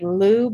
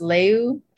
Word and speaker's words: Liu: Well, I think he pronounces it like Liu, but I think it's supposed to be pronounced Leu. – Okay. – He grew up in Canada, so I think he has a slightly different pronunciation.